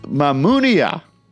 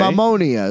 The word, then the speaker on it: Mamunia: It starts off with Mamunia. – Or Mamonia. – Yeah, well, how do you say it? I mean, it's spelled Mamunia, right? – Mamonia is what they're singing.